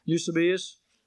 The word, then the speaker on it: Eusebius: There is a 4th century historian by the name of Eusebius. I don't know if I said that right, Mr. Bean. Did I say that right? Eusebius? Okay.